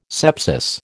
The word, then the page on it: sepsis: American English